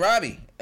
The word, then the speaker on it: Robby: I was writing Robbie. Robbie all the way to third grade. R-O-B-B-Y.